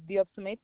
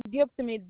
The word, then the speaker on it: Diop Smith: My first name is Yaye, Yaye, and my last name is Diop Smith. – Can you spell that for me? – Diop Smith, DIOPSMITH.